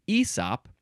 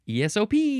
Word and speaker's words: ESOP: You could also pronounce it ESOP, and it tells me both pronunciations. It tells me ESOP, and it also tells me how to pronounce those letters, ESOP. And which ones are emphasized? The P is emphasized.